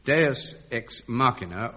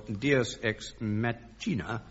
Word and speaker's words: deus ex machina: I don't know whether to use the old or the new pronunciations. I'd better use both. This finds me in great difficulties. Dennis Norden, yours is, in my pronunciation, Deus ex machina, or it might be in yours, Deus ex machina.